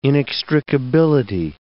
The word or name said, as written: inextricability